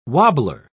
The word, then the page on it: wobbler: British English